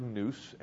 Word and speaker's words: nous: And one is the Greek word nous, N-O-U-S, is the way we would spell it, which is the word for your mind.